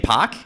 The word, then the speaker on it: Pak: Here's the first thing we're going to explain is that you actually pronounce his name Pak, and now I learned a thing.